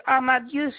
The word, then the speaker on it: Amadieu: Amadieu Janine. – Comment ça s'écrit? – A-M-A-D-I-E-U. – A-D-I-E-U.